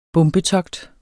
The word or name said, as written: bombetogt